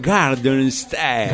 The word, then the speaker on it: Garden State: Ah, Gardel Stein, dijo, se llama Gardel Stein, se llama la película. No, Garden State, ahora sí, Leo, tengo que hacer la pronunciación. Garden State.